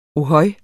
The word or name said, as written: ohøj